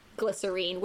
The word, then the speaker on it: glycerin: And he would always contradict me on my pronunciation of the word glycerin, which he insisted was glycerine. And I said, maybe you Kiwis say glycerine, but in America we say glycerin.